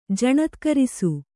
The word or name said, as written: ಜಣತ್ಕರಿಸು